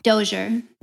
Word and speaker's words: Dozier: Is it the Dozier? – Dozier.